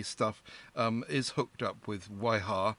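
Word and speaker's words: Wiha: I'm thinking it sounds more like a sort of Southern American Weeha. I don't quite know. I said Viha. W will be pronounced as a V sound, so Viha, oh, yes.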